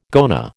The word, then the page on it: goanna: American English